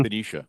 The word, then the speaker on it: Benicia: the only remaining oil refinery in the town of Benicia, I believe it's pronounced. And Benicia. – Hopefully Benicia, excuse me.